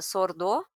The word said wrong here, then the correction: sourdough: É, essa é uma expressão inglesa daí, é sourdough. Sourdough, é, tá.